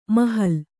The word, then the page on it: ಮಹಲ್: mahal